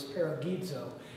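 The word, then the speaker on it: paragizo (παραζηλόω): The pronunciation of the word is paragizo, and it is used only twice.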